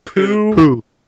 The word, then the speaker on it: Pujols: And next to his name, it tells you how to pronounce his name, and it literally is Pujols.